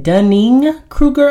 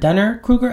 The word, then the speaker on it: Dunning-Kruger: One, I was on a roll and it is actually pronounced Dunning-Kruger effect, not Dunner-Kruger effect.